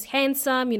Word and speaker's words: handsome: By the way, when we say handsome, the letter D in this word is silent. Yes, there is a letter D, okay? It's silent. So we pronounce handsome.